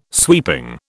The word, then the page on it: sweeping: British English